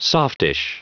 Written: softish

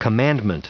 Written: commandment

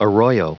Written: arroyo

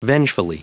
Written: vengefully

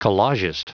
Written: collagist

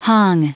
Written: hung

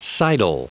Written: sidle

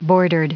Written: bordered

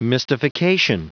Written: mystification

